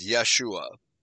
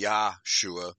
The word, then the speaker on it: Yahshua: But now, in this verse, the word salvation is, well, they promote it as Yeshua, Y-E-S-H-U-A. But I think it should be Yahshua, Y-A-H. S-H-U-A, that would be the correct Hebrew pronunciation.